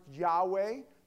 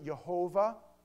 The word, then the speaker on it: Yahweh: If anybody doesn't know what that is, that's pronounced Yahweh. Some people pronounce it Jehovah.